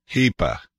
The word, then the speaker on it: Hepe: Hepa. – Yeah. – Okay, H-E-P-E.